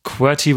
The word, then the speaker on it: QWERTY: QWERTY würde ich jetzt mal aussprechen. Wie dieses Keyboard-Ding halt.